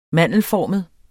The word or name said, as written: mandelformet